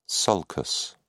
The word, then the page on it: sulcus: British English